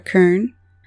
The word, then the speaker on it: Cerne: You will hear a familiar name like the city of Cern or Kern. It wasn't until Latin that the C's started to make the soft C sound, so C's were pronounced as K's in ancient Greek.